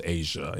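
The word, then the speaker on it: A'ja: It's not AJA? – It's pronounced Asia. It is AJA. But okay, that apostrophe, you use it. Asia, the country.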